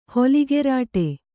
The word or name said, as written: ಹೊಲಿಗೆರಾಟೆ